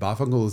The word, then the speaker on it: Vaffanculo: And Bafangul is a New York Italian pronunciation of Vaffanculo, which means go fuck yourself.